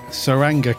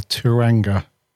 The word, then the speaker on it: Tsuranga: With a silent T. – With the silent T. We were unsure on the T or the S, weren't we? – Yeah, we weren't sure how to pronounce it, were we? What is it? To Sangra. – Yeah, I didn't know if it was the Turanga or the Saranga, but we got it right, as far as I'm concerned.